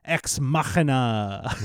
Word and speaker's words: Ex Machina: This episode, we are discussing the film Ex Machina. – Ooh, is that the official pronunciation? – Yeah, I watched a YouTube video, so that's, I'm pretty sure, how it's pronounced.